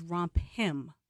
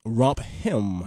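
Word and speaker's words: romphim: It's rompers, but the male version is romp him. – romp hem. – Yes. – So you gotta say it like that.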